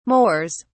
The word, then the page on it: moors: British English